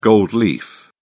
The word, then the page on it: gold leaf: British English